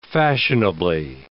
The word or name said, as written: fashionably